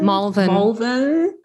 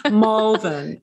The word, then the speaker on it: Malvern: Malvern. You gotta go all fancy on the A's. – Malvern. – Yes. And, especially and in Malvern.